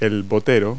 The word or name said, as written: boater